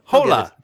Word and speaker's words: Hola: Hola to our Spanish Hola. – Listeners. – That's right. Hola. – Hola? – Yeah. – All right, whatever you say. – Don't pronounce the H.